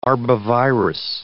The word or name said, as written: arbovirus